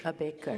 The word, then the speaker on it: Ambedkar: Ambedkar.